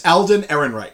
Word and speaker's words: Alden Ehrenreich: His name is Alden Ehrenreich.